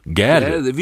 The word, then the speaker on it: Gade: Königstochter von Nils Wilhelm Gade oder Gorde, glaube ich, ausgesprochen. – Gade, wie Straße.